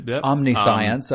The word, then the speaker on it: Omniscience: Omniscience, I believe is how it's actually pronounced.